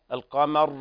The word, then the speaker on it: القمر: يا علا الراء لا تزبزب حرف الراء بعد النطق، يعني تقول القمر ما في قلقلة، بالسكون للبشر القمر. واضح؟ – طيب.